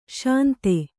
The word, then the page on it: ಶಾಂತಿ: śanti